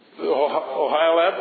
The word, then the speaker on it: Oholiab: How do you say his name? Oholiab? Okay.